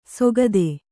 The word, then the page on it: ಸೊಗದೆ: sogade